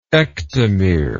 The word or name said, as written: ectomere